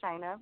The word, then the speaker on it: Shina: So, again, my name is Shina, pronounced like China, but with an S.